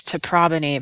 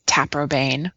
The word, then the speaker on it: Taprobane: Well, the beginning, there's this little part that says, you know, the historic classical pronunciation is Teprobony, but most people say Taprobane.